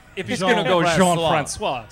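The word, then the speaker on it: Jean-François: Like, he introduced himself as Ricky Jean-Francois, so then I was pronouncing it Ricky Jean-Francois for a while later, for a time after that. Then it was brought to my attention that it is Jean-Francois, so you just got to make sure you get the pronunciation down.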